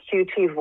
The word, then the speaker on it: QT: So I do want to ask your listeners to check out, just Google QT Voices. It's Queer and Trans Voices in the URL. And YQT rhymes with UT. Sounds like cutie.